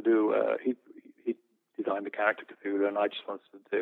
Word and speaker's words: Cthulhu: Cthulhu, yeah.